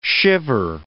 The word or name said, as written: shiver